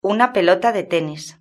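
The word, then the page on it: una pelota de tenis: European Spanish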